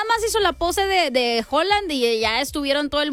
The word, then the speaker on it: Haaland: De Haaland, porque bueno, ya también me dijeron que no se dice Haaland, pues que se dice Haaland.